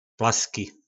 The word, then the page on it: plaski: po nasimu